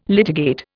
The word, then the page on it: litigate: Indian